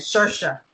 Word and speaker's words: Saoirse: I think that I cannot pronounce her first name. Saoirse, thank you.